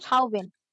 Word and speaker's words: Chauvin: Derek Chauvin. – How do you pronounce his name? Chauvin.